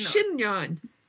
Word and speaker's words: chignon: Chignon. Chignon.